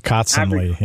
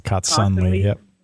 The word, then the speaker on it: Kotsanley: It's Kotsanley. Yeah, Kotsanley. Yep. – Kotsanley. – Almost like constantly. Like he constantly punts.